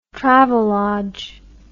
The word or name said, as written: travelodge